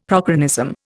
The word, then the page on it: prochronism: Indian